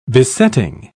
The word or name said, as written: besetting